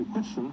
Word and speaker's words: Whitson: I said Watson. It's Whitson. Sorry. Because W-H-I-T-S-O-N. So again, it's spelled W-H-I-T-S-O-N. So it's Whitson.